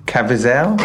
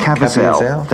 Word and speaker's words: Caviezel: Jim Caviezel? – Caviezel. There you go. Caviezel.